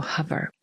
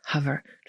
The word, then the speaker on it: hover: że czytamy je jako hover, czyli przez A. I to jest prawda, nie wycofuję się z tego. Natomiast ważne jest, by powiedzieć, że tak wymawiają to słowo Amerykanie, a więc to po amerykańsku to słowo brzmi jak hover, czyli rymuje się z cover. Przykryć. Natomiast Brytyjczyk powie hover.